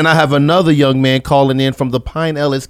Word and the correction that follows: Pinellas: County area. Pinellas. I'm going to get it right.